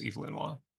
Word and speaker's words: Evelyn Waugh: No, that's how you pronounce it. It's Evelyn Waugh.